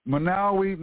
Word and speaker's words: Monowi: Manawi, Nebraska. Somebody, I think, Petit, bought this up like a week or so ago. Manawi, Nebraska, and that's spelled M-O-N-O-W-I, Manawi, Nebraska.